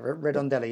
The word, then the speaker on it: Redondela: Redondela, yeah. – so and that goes up, continues down the central, but then once you get into the day's walk after Redondela into Pontevedra, it deviates.